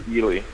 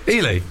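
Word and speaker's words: Ehle: How did you pronounce that? Jennifer... Do you know Jennifer's last name? Ellie? – Jennifer Ely. – Ely, there you go.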